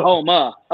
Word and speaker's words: Houma: H-O-U-M-A. Oh, Houma. Okay. – Houma is actually how they say it. – Okay. – That's Houma. How the Cajuns say it, Houma.